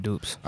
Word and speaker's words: Dupes: Yeah, because, like, when I first saw the credits and I saw it was Notebook P and, is it Dupes? Is that how you say his name? – Yeah, Dupes.